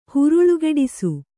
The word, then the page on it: ಹರಳುಗಟ್ಟಿಸು: haraḷugaṭṭisu; Kannada